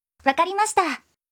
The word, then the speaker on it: Wakarimashita: ¡Wakarimashita!